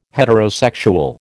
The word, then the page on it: heterosexual: American English